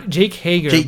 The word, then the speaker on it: Hager: He was pronouncing it as Hagar, but then because Jericho had Sammy Hagar on the mind, probably. I don't know, but it's not Hagar. I think it's Jake Hager.